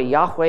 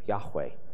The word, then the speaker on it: Yahweh: When you trace out the roots, and by the way, Yahweh is now what most scholars, high probability, believe this is the pronunciation of this name, Yahweh. It's kind of an aspirant sound, Yahweh.